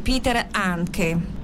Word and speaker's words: Peter Handke: Verdi Colline dell'Africa è un testo che lei appunto ha scritto, che nasce però come tributo a uno scrittore e drammaturgo austriaco, non so se pronuncio bene, Peter Handke. – Lo pronuncia benissimo, che io sappia, sono una cultrice.